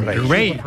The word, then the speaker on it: Raye: Ray. Ray. Se escribe Raye. Raye con ella.